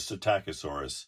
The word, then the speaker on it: Psittacosaurus: I think we had agreed early on in a previous show that it was Psittacosaurus.